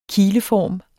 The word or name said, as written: kileform